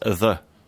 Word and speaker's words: ye: And it's not pronounced ye, it's pronounced the. The Y in Anglo-Saxon is pronounced the.